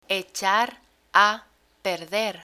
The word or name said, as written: echar a perder